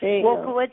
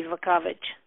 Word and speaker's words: Wulkowicz: My father-in-law told me if it was in Polish, it would be Wulkowicz. – Huh. – The W's are pronounced Z in Polish.